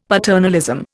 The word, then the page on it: paternalism: Indian